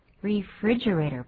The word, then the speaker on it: refrigerator: Refrigerator. Refrigerator. 不知道为什么很多人会念成 refrigerator，那是错的，重音在第二个音节。